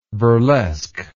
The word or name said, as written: burlesquer